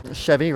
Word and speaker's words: Chevi: Yeah, Chevy Rabbit. So it's spelled C-H-E-V-I and then Rabbit like R-A-B-B-I-T.